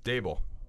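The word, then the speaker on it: Daboll: But I got to tell you, if you told me Gable how does he pronounce his name, the Giants coach? – Dable. – Yeah, Dable. Yeah, I was putting G in it.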